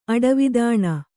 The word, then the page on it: ಅಡವಿದಾಣ: Kannada